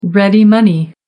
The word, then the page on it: ready money: American English